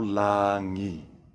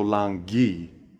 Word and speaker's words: langi: Remember that in the Samoan language, the G is always rolled together with an N to create the ng sound you hear in the word sing. Thus, it isn't pronounced lalo langi, it's pronounced lalo langi.